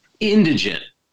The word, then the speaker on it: indigent: Let's see, how's this word pronounced? Indigent. Am I saying that right? – Yes.